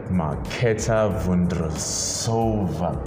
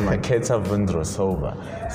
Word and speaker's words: Marketa Vondrousova: And it was the teenager, Marketa Vondrousova. Goodness me. I said the name wrong. Yeah, but it was Marketa Vondrousova.